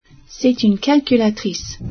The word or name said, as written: calculatrice